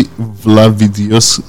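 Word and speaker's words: Vladivideos: What is the Lavidios scandal? – It's actually Vlavidios, and it is spelled like Vlad, V-L-A.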